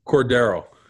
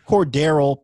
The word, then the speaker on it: Cordarrelle: Is it Cordarrelle or Cordell? How do you say his name? – Cordero. – Cordero Patterson. Shout out.